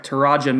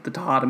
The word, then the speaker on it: Taraja: For, I'm going to say his name right, Taraja Mitchell. I practiced it like all week. I was very upset about last the Tahata Mitchell debacle.